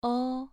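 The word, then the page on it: ou: tone 2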